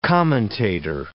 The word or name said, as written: commentator